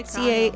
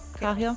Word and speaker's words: Cahill: How would you say that? Cahill? Cahill? – I'd say Cahill. – C-A-H-I? Cahill? – Cahill's good too.